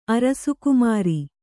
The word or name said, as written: ಅರಸುಕುಮಾರಿ